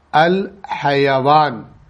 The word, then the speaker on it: الحیوان: ایک لفظ ہے جو اسی ہا یا اور یا سے بنتا ہے جس کو ہم حیوان کہتے ہیں, لیکن اس کا عربی زبان میں جو صحیح تلفظ ہے الحیوان, الحیوان. الحیوان کے دو معنی ہیں,